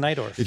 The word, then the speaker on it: Najdorf: Fischer facing the night orf doesn't count. – Yeah. – Yes. – Yeah. Okay. The night orf, he says. – N-A-J-D-O-R-F. Najdorf. Pronounced night orf.